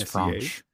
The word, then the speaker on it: Messier: Mark Messier. Am I pronouncing that correctly? – Messier, sir. – Mark it's Messier.